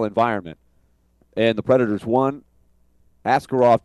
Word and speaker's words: Askarov: He went to San Jose and he said, "Yeah, call me Askarov." Where after we were with him for 3 years in Milwaukee, and we went over it, how you say it really in Russian, which is Askarov.